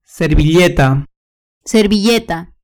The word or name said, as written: servilleta